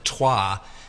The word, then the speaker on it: trois: Instead of trois, T-R-O-I-S, they pronounced it as if it was T-W-A-T.